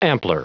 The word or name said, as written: ampler